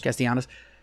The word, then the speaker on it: Castellanos: How do you say his name? – Castellanos.